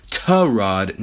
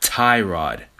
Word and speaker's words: Tyrod: He told me that Tyrod Taylor's name is actually pronounced Tyrod, not Tyrod.